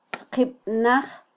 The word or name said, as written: tzqibnaj